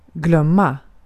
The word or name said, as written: glömma